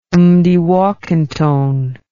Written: Mdewakanton

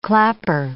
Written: clapper

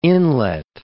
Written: inlet

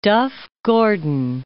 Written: Duff-Gordon

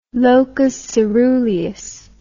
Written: locus caeruleus

